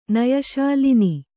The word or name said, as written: ಲೇನು